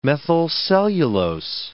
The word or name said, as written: methylcellulose